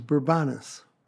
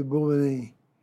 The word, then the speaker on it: Bourbonnais: there's a town not far from Kankakee that was Bourbonus. For the bicentennial, they changed the pronunciation to Bourbonnais, which is the way it would be pronounced in French.